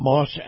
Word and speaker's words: Maus: And before we get to the Watchdog on Wall Street, Chris Markowski, I want to tell you and remind you about Moss Acura, who brings you this segment of the program. Now, Moss is spelled M-A-U-S, and if you love the feel of a high-end SUV, you're going to love Moss Acura.